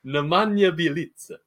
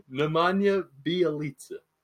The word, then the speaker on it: Nemanja Bjelica: Nemanja Bjelica. Nemanja Bjelica.